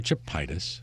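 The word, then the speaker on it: Chepaitis: Barbara Chepitis, yes. – Rhymes with phlebitis.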